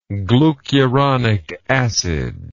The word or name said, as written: glucuronic acid